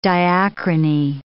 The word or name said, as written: diachrony